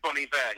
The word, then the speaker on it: Iver: Well, it is Bon Iver. He's a French Canadian, and Bon Iver means good winter.